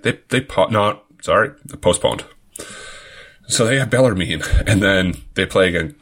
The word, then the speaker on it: Bellarmine: Am I saying that wrong? – Bellarmine, I believe.